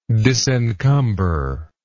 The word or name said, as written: disencumberment